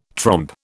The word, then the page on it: trompe: British English